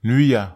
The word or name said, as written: nyia